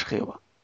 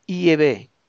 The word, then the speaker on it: Yahvé: Lo que pasa es que no es correcto llamarle Jehová. No es Jehová. En la pronunciación del tetragrama o tetragrametrón es Yahvé. Yahvé o Yahvé. Yahvé o Yahvé, que es lo mismo. Esa parece ser que la auténtica pronunciación.